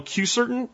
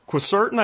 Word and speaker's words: Quercetin: Remember that zinc and supplemental Q-certain, or Quercetin, I think is the right way to say it, Quercetin, and green tea extract also get zinc into your cells.